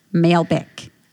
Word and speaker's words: Malbec: And this is a Bota box, B-O-T-A, and it's actually a 2016 Malbec. Malbec.